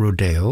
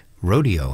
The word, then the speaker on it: Rodeo: And it's his performance we're going to get with San Francisco Symphony here of Aaron Copland, who pronounced his work Rodeo, not Rodeo or anything like that.